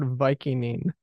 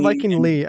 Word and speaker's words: Vikinging: Well, not Vikingly. After Vikingly, okay. You say Viking-ing.